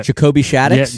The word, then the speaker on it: Jacoby Shaddix: Jacoby Shaddix? – Yeah, Jacoby Shaddix. That's how you say it.